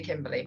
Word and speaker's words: Kimbalee: but my name is actually Kimberly, but it is spelt weird. So it's spelled K-I-M-B-A-L-E-E. – Okay. – So Kimber and then Lee.